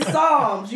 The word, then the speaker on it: Psalms: He put the pee in Psalms. You get it? Because the pee is silent?